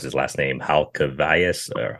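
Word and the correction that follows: Halkias: Halkius, yeah.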